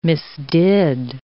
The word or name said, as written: misdid